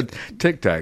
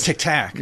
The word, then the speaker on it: Tic Tac: No, that's a tic tac. – Oh, it's tic tac. – Yeah, tic tac. You got, you know, you gotta not tic tac. – You're putting the emphasis on the wrong syllable.